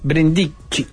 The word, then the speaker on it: Brindicci: Yo creo que sí, que es una muy buena foto del fotógrafo Brindisi, ¿no? – Brindicci. – Brindicci se llamaba. – Sí, porque se escribe con doble C.